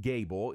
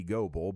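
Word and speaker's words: Goebel: And if you see it printed, it's G-O-E-B-E-L, G-O-E-B-E-L, but pronounced Gable. If you know Baylor football, you know that. But if you just saw it written out, you might think, it must be Goble, but it is Brad Gable.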